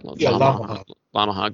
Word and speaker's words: Lamaha: You go back to Lamaha. Am I saying that right? – Lamaha Gardens. – It's like Yamaha with an L.